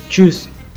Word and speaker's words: Tschüss: Auf La segunda es Tschüss.